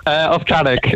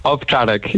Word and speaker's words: Owczarek: Owczarek is the pronunciation. – Owczarek. – Owczarek, yeah.